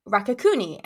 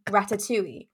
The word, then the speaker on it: ratatouille: The Rakakuni scene where Evelyn is trying to express to her family about, you guys were like Rakakuni. And she's quite clearly in this moment mispronouncing Ratatouille.